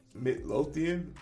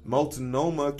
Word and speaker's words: Multnomah: The Midlothian? How do you say this shit? I love this feature. – Multnomah